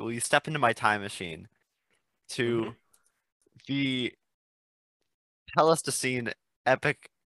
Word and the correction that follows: epoch: Ice Age. I don't know how it's pronounced. – Epoch, I usually think.